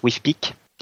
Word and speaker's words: WFPC: Donc ainsi, par exemple, Jim Wetfall, donc directeur du principal instrument, le Wide Field Planetary Camera, qu'on abrège en WFPC, et en fait que les gens prononcent WIFPIC.